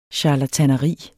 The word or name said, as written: charlataneri